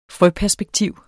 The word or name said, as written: frøperspektiv